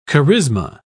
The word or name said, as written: charisma